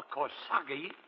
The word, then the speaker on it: corsage: A corsage of flowers. – Corsage? Don't you mean corsage, dear boy? A-G is pronounced Ars, you know. As in garage, massage.